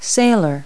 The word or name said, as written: sailor